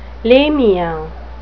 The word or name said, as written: lamia